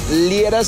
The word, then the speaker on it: Liera: Búscame por mi nombre, Fernando Liera, se escribe L-I-E-R-A, y ahí me dices: oye, Fer, queremos que la ruta del amor vaya al cruce de tal avenida con tal avenida.